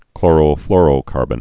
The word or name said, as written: chlorofluorocarbon